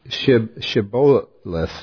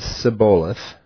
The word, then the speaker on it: Shibboleth: Then they would say to him, then say, Shibboleth. See, I'm not, you know, Shibboleth. And he would say, Sibboleth. He would leave out the H.